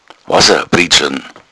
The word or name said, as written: priedschln